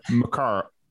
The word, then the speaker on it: Mikara: We have Mikara.